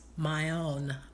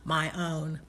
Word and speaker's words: ma'on: Dwelling place in Hebrews is pronounced as ma'on, which I think is kind of interesting, ma'on.